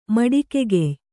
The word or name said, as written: ಮೆಟ್ಟುಗೆಗೆಯ್